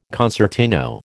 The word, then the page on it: concertino: American English